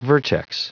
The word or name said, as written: vertex